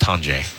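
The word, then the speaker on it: Tonje: I've been saying Tanjay. I even have it in my notes. It's pronounced Tanjay.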